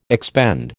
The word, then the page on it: expend: American English